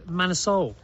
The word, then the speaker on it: Manor Seoul: It's Manor Seoul. – Man of Seoul. – As in the capital of Korea.